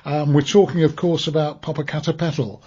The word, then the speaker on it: Popocatépetl: I've got to learn how to pronounce Popocatépetl.